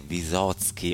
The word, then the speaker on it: Vysotsky: E meno male che questa sera abbiamo proprio la fortuna di avere una compagna in studio che mi dice che la pronuncia esatta è Vysotsky o Vysotsky, grande Vladimir, grande poeta, come ho detto, scrittore, songwriter e artista russo.